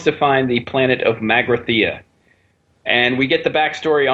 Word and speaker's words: Magrathea: Magrathea.